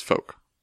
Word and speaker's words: folk: I think you can say the L. – Folk. – Mm-hmm. Yeah. – But I don't think most people do.